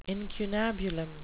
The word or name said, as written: incunabulum